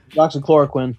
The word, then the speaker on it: hydroxychloroquine: Hydroxychloroquine? Did I pronounce that right? – Hydroxychloroquine.